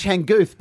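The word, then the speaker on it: Changkuoth: And how's his first name go? – Well, it's Changuth, but no one's bothered to spend 3 seconds to try and say his first name, so they just throw CJ.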